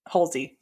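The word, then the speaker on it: Hussey: There's also the main character, Jess, played by Olivia Hulsey. Yes. Hulsey. Yep. Said that right.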